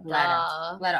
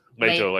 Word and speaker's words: Leto: I always say it Leto. Leto, like the Spanish pronunciation with it. – The Duke Leto. That's how the Audible guy says it. Duke Leto.